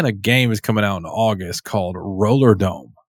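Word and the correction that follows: Rollerdrome: Roller Drome or something? – Drome. Yeah. Sorry, I said it wrong.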